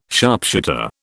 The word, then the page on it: sharpshooter: British English